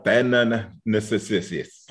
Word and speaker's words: Athanasius: Uh, it's Anna Athanasius.